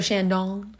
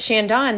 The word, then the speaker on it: Chandon: But then I think from an experience standpoint, I liked Chandon. Is that how you say it? You would say it that way. – So Chandon. But Chandon, that was so fun because we had like the picnic.